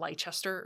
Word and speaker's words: Leicester: And then BFL, or Blue-Faced Leicester, and that's spelled L-E-I-C-E-S-T-E-R. It's one of those words where if you've never heard it out loud, you might think it's like Leicester or something, but it's Leicester.